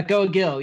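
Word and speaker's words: Goa Gil: Yeah, Goa Gill, yeah.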